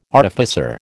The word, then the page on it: artificer: American English